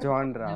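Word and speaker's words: genre: है क्या नहीं जो it's called जॉनरा ओनली इट्स प्रोनाउंस इन टू डिफरेंट वेज जॉनर and जॉनरा you can check it on the dictionary क्या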